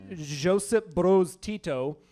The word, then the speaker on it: Josip Broz Tito: Yugoslavian president Josip Broz Tito.